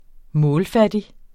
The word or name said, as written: målfattig